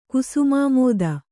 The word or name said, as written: ಕುಸುಮಾಮೋದ